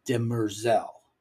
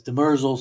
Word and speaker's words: Demerzel: Okay, if you've been watching the show, you've noticed they pronounce the robot's name as Demerzel. I've been pronouncing it as Demerzel since I read the books in the 80s, so I'm probably going to keep doing it that way.